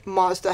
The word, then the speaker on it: Mazda: And so in the first interview, Twitchell was like, never met John, and also I'd never seen a red Mazda hatchback. – Mazda. You said it wrong.